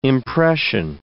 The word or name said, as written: impression